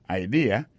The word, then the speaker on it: idea: T-H-A-T, apostrophe S, good, G-O-O-D pour good, ça veut dire bon, bonne idea. C'est I-D-E-A. Ça veut dire idée. That's a good idea. Alors, accent vient tomber dans la deuxième syllabe là.